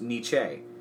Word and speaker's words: Nietzsche: Congratulations first piece of information on Nietzsche, I'm pretty sure it's actually pronounced Nietzsche.